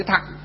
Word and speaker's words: thặng: Tiểu có nghĩa là nhỏ, chữ thừa, chữ Hán á, đọc là chữ thặng. Việt Nam chúng ta thì Phật Pháp á, đọc chạy đi là chữ thừa.